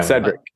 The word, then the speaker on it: Cedric: Is your name pronounced, because we said it's the start, is it pronounced Cedric or Patrick? Yeah. – Wow. Cedric.